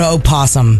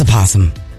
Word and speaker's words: opossum: No, the O is silent. – Are you sure? – It's a possum. – Let's get the pronunciation. – Opossum. – Opossum. – No. Opossum. – Well, but the O is not silent.